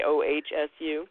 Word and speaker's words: OHSU: You don't make it into a word, you just say OHSU? – That's right.